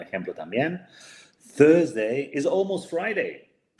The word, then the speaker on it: Friday: Ok, y se dice así, Fry, Friday.